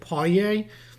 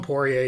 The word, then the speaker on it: Poirier: Boston and Oklahoma City have made a trade today for Vincent, is it pronounced Poirier? Vincent Poirier? – Yeah, pretty close. It's like Poirier. Okay. There's like a French in there, but just call him Poirier, you're good enough.